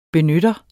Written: BENYTTEREN

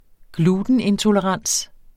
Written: glutenintolerans

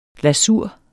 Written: lasur

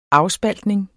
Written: afspaltning

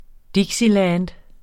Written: dixieland

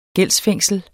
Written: gældsfængsel

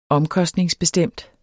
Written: omkostningsbestemt

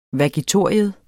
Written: vagitorium

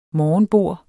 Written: morgenbord

